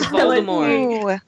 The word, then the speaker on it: Voldemort: Devra says, was Voldemort pronounced with a T or a silent T? Curious. – It was a silent T. They said Voldemort. Yeah, the entire time they said Voldemort.